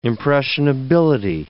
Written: impressionability

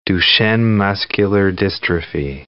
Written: Duchenne muscular dystrophy

